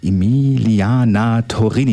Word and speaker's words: Emiliana Torrini: Ja, Emilia Torrini hören wir jetzt. Ich hoffe, ich habe es richtig ausgesprochen. Emiliana Torrini, jetzt genau.